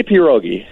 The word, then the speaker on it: pierogi: I say pierogi. It's a very hard emphasis on that P, pierogi.